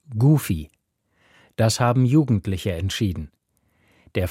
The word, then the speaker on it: Goofy: Das Wort Goofy wird Goofy ausgesprochen.